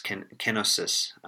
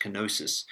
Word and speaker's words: kenosis: So if you check out Philippians 2, you can actually see how that plays out in this idea of Christ's kenosis, often mispronounced kenosis.